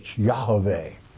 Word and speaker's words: Yahweh: So, but it is Y in the Hebrew tongue, Yah, which is YAH, not J-A-H, Y-A-H. There's no J's in the Hebrew alphabet. Y-A-H-A-V-E-H, Yahweh, okay? The correct pronunciation of the sacred name.